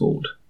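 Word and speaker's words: Voord: And that's right, it is pronounced Vord.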